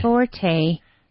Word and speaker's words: forte: Punto fuerte is forte. Forte. I'll spell it for you. We use the French word. So it's F-O-R-T-E, forte. Paco, mm-hmm.